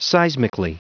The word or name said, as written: seismically